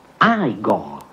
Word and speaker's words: Igor: Tu devi essere Igor. – No, si pronuncia Igor. – Ma mi hanno detto che era Igor.